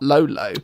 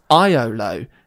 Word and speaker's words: Iolo: It's not Lolo, it's Iolo. That's an I.